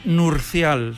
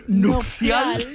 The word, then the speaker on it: nupcial: Sí, es que yo digo que hasta los 13 años pensé que era nurcial. – Nurcial. – ¿Sí? – Y claro, me gusta decir nupcial, que lo he dicho mal. – Has dicho nupcial. – He dicho nurcial. Nupcial, sí, sí.